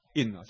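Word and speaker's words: 殒: 这坚决是空大哈，那所有的世界的东西都是在空大当中，我们地球在空大，所以这个什么开悟的人，地球全部什么东西，全部什么，等于是香消疫病的什么消病是吧？女生死了叫香消疫病是吧？疫病不是那个一个这个一个歹字旁在一个，那怎么读？印是吧？印哦，香消疫印不是疫病呢、啊？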